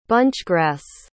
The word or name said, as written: bunchgrass